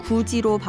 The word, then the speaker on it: 굳이: 디귿받침의 굳다에 이가 결합한 말은 굳이로 발음되지만 표기는 굳이입니다.